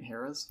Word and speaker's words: Harrah's: Harrah's? – I don't know. – H-A-R-R-A-H.